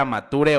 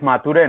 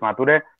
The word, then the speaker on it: Mature: Sí, sí, decir que es Mature, ¿no? Como, como se escribe, se lee, es Mature. Eh, mucha gente usa el inglés y dice mature, pero, pero no, es mature.